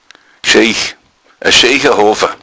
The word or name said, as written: schiach